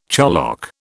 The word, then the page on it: charlock: British English